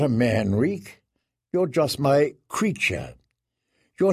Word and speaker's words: Reek: "You are no prince, you're Reek, just Reek, it rhymes with freak.